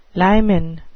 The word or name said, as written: limen